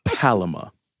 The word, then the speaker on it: palama: The next word is Palama. – That sounds way too simple. I know I would get that wrong. – Palama.